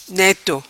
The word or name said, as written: Neto